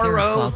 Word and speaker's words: Seroquel: S-E-R-O. Seroquel. – That sounds familiar. – S-E-R-O-Q-U-E-L, Seroquel.